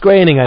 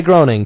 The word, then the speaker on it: Groening: Groening, it's pronounced Groening. I know you want to say Groening, people.